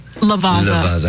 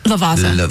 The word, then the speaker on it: Lavazza: Lavazza. – How do you just say it if you're trying to be cool? – Lavazza.